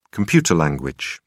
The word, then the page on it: computer language: British English